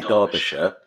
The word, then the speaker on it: Derbyshire: How do you say it? Derbyshire or Derbyshire? – Oh, I think it depends which part of England you're from. Oh, I say Derbyshire.